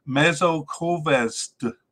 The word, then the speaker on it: Mezőkövesd: It's Mezőkövesd in Hungary.